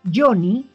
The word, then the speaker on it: Yonny: Johnny, que se escribe con Y, y A de Albarracín.